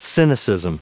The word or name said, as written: cynicism